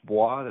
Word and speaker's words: Bois: My last name's B-O-I-S. It's like Bravo, Oscar, India, Sam, you know, spelled out phonetically. Because I know it's French. It's a little tough to pronounce and write for many people.